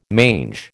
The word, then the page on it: mange: American English